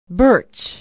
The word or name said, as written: birch